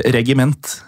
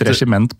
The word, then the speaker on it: regiment: Ja, rett og slett, og det uttales visst nok regiment. – Regiment? – Ja, vi har jo tenkt regiment på grunn av regissør og så videre.